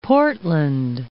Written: Portland

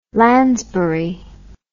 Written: lansbury

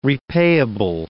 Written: repayable

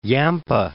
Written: Yampa

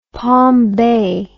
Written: palm bay

